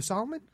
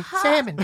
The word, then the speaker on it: salmon: And I just go, the salmon? Sure. – Absolutely. – Because I don't have enough balls to say salmon. – Yeah. Why would you? – Well, they like to pronounce the silent L.